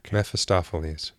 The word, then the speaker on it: Mephistopheles: Like, they call him Mephistopheles.